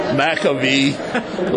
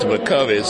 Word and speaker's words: McCovey: They called me McCovey, McAvee, lots of different ways, you know. So Johnny Antonelli knew that I liked McCovey, so he told everybody how to pronounce it. He said, rhymes with lovey-dovey.